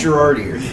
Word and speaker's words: Girardeau: It's Girardeau. – Yeah, Girardeau. – I think it's a soft J. – Girardeau, yeah. – And then the U is silent.